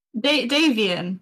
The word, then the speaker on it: Davion: D-A-V-I-O-N. – Davian, like Damien, but with a V.